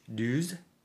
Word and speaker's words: douze: Oh, but how do you say 12 in French? – Douze. – Deuce? Are you sure about that? – D-O-U-Z-E.